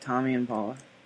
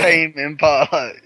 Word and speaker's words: Tame Impala: Why are you calling it Tommy Impala? – Because that's how I pronounce it. I pronounce the E. – Why? It's Tommy. Tame Impala, you crazy.